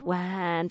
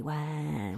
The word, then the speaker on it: whaine: We spell it wan, W-H-A-I-N-E, wan. – Wan time. – So it can be kind of like wine or wine.